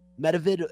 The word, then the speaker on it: Medvedev: Open today against Daniil, I think it's Medvedev, right? Medvedev is the way you pronounce his last name.